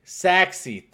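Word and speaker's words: Sachse: Let me help you out. I've been in the market a little while. Saxy, thank you so much. I've been pronouncing that wrong this entire time.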